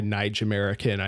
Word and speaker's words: Naijamerican: Um, I've I've seen her refer to herself as a Naijamerican. I assume is how that is pronounced. Um, yep, that's how is, she said it.